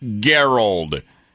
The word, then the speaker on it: Gerald: That's right, Gerald. He pronounces his first name with a hard G.